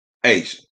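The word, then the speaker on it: A'ja: A apostrophe J-A is pronounced Asian.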